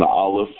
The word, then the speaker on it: aleph: And it's pronounced Aleph. But if you have root vowels, the Aleph has the A sound. A. The Lamed has the La sound. But when we say Aleph, it's not La, it's Le.